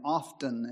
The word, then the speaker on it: often: My daughter, whom I have not corrected on this issue for years, has the habit of saying often instead of often. I don't know how many people are aware of this issue. But the T is silent.